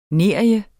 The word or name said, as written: nerie